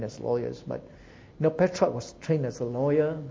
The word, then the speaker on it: Petrarch: In English, we'll pronounce him as Petrarch, you see.